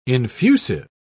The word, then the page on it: infusive: British English